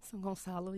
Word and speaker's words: São Gonçalo: There's a quote that came out of, if I say the name wrong in Portuguese, please tell me how to pronounce it. This town is São Gonçalo. – São Gonçalo, yes. – Gonçalo? – Mm-hmm.